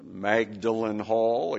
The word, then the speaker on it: Magdalen: Magdalen Hall, again, the English pronounce it strangely, Magdalen Hall, as the preparatory school for Magdalen College at Oxford University.